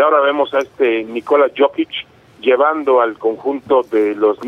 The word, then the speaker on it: Jokić: el poder de Nikola Jokić, no sé si lo pronuncie bien, el que le dicen el inmortal, ¿sí?, de los Denver Nuggets? – Sí, Jokić, otro jugador, un jugador serbio de gran, gran condición.